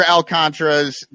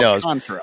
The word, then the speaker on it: Alcantara: Knows, because there is an accent mark there. Because I hear a lot of people Alcantara, which normally the second to last syllable is going to get your accent, but not here.